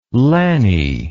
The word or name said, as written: lanny